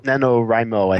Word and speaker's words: NaNoWriMo: NaNoWriMo, I think most people pronounce it as.